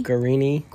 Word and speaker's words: Guarini: Guarini.